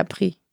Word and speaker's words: Prix: Pre-Europe, spricht man das so aus? – Den kenne ich nicht. – P-R-I-X, spricht man das dann Pre-Europe aus?